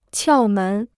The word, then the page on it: 窍门: qiào mén